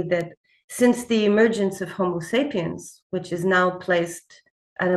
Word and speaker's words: Homo sapiens: Today, Homo sapiens are the only only species within the Homo genus. Am I saying that correctly? Left? – Yes, you are.